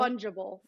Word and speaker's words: fungible: Non-fundable. – Fungible. Fungible. – Non-fungible. For sure. – I assume that's how you say it.